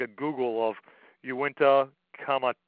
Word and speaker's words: Uinta: Uinta. It's a, How do you spell that? – It's U-I-N-T-A.